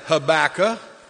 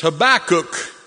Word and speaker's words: Habakkuk: Doesn't rhyme with tobacco. It's not said Habakkuk. It's Habakkuk. And please take your time to get there.